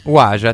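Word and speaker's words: Wadjda: Come si dice? Non mi ricordo più come si pronuncia. – Wadida. – No, è lei, è lei. Della bambina. Wajda. Secondo me è Wajda. – Wajda, titolo originale di La bicicletta verde di Ayfala Mansour, che è il film di cui stiamo per parlare.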